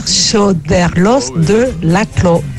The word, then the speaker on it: Choderlos de Laclos: Hola, se dice Pierre Choderlos de Laclos.